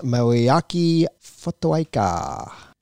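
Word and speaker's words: Moeaki Fotuaika: Number eight for the Titans is Moiaki Fotuaika.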